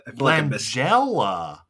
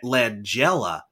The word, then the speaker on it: Langella: Is it Langella or Langello? What is it? How do you pronounce it? – Langella. – Langella.